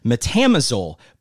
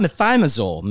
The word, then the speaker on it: metamizole: Metamizole, but not methimazole, metamizole.